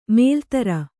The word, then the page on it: ಮೊಲೆತೊರೆ: kannada